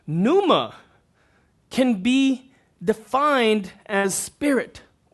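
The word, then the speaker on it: pneuma (πνεῦμα): But it would be pronounced pneuma, and you've heard me share this word many times.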